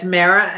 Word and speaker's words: Mara: Did I pronounce the last name correctly? – Yeah, it's Mera, M-A-R-A.